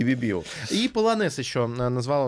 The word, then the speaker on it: полонез: Ну, дансе полонез. да, понятно. Полонез. – А, ну, ударение на последнем во французском всегда.